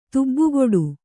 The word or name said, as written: ತುಬ್ಬುಗೊಡು